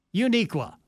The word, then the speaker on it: Unique: Bobby had an employee named Unique, U-N-I-Q-U-E, pronounced Uniqua.